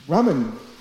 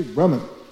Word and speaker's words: Brahman: But in fact, Brahman, this Brahman effulgent, there are no diacritics in this word, no long A. It's actually Brahman. Yeah. But we normally say Brahma. It is not. Brahma is Brahma. That's a long A. That's the person A. This is Brahman.